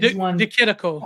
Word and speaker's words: De Chirico: I think I'm saying his name right. – De Chirico. Oh, yeah. – Is it De Chirico? Okay.